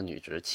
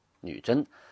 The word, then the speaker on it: 女真: 辽兴宗皇帝叫耶律真，哎，他用的就这个真字所所以呢，那女真呢，那俩点去掉啊，叫女直吧，就是为了写成女直，就不能写这个女真。你可以叫成女真，但是写要写成女直，所以现在我们读成了女直，其实应该读成女真。